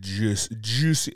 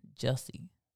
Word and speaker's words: Jussie: Juicy, as Dave Chappelle say, Jussie Smollett. – That's a kind of... – That's how his name's spelled. It ain't like it's spelled Jesse. – Like Aussie, it's like Jussie.